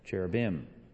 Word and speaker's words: cherubim: And the plural, how do you say the plural? Cherubim.